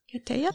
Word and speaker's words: ketiya: For, I don't know how you pronounce it, the ketiya? Ketiya? – Yes.